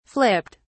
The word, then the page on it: flipped: British English